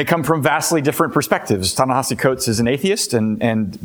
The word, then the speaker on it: Ta-Nehisi: His name is Ta-Nehisi Coates. You have to practice pronouncing his name. I always have to remember, how do you spell that and how does it sound? But his name is Ta-Nehisi Coates.